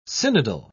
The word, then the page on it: synodal: British English